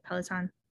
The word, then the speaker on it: Peloton: Peloton? – Yeah.